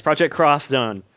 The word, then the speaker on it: Project X Zone: Yes, Project Cross Zone. – Is that how you pronounce it? – Cross Zone? – Anytime Japanese people use that symbol, it's cross.